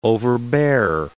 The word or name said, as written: overbear